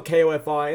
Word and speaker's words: Ko-fi: It might be coffee, but it's spelled K-O-F-I, and that's weird, so I say it Ko-fi.